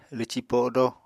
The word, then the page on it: lwci-podo: Apatani